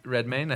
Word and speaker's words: Redmayne: What about an Eddie Redmayne? – Yeah. – How do you pronounce his name? – Redmayne, I think. – It is Redmayne, yeah.